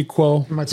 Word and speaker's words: Kuo: Apple's HomePod may make an unexpected return later this year, according to reputable Apple analyst Ming-Chi Koo. He recently said that Apple Ming-Chi Kuo.